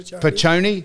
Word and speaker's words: Picone: Now, Chris Piccone, P-I-C-O-N-E. How would you pronounce that? – Yeah, Piccone.